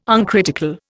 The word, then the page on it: uncritical: Indian